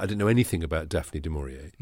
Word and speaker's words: du Maurier: So we have to talk about Daphne du Maurier. – Du Maurier, as she liked to pronounce it.